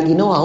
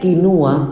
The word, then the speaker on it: quinua: que la forma correcta de decirlo sería quinua, con U, no con O.